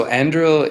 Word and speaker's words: Anduril: They're called Anduril Towers. Is that, am I pronouncing that right? – Yeah. So Anduril is one of the main companies who is contracting with the U.S.